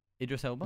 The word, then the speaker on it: Idris Elba: Idris Elba.